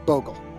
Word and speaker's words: Bogle: and his last name's actually pronounced Bogle.